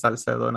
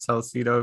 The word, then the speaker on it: Salcedo: It's Salcedo, not Salcedo, if that's what you're trying to say.